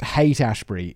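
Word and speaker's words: Haight-Ashbury: Apparently, it's pronounced Hate Ashbury. It's spelled like Hort.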